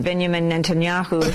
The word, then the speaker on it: Benjamin Netanyahu: I always thought it was Benjamin Netanyahu, not that, Ben blah, blah, Netanyahu. So just play this and you hear your pronunciation.